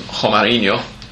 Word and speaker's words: Romarinho: With a goal from his first ever touch in the Copa Libertadores from Romarinho.